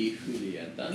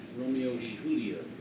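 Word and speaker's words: Romeo y Julieta: Well, if I butcher the name, it's Romeo e Julieta Reserve. – Well, if you can butcher it, that's right. – Yeah. – It would be e Julieta.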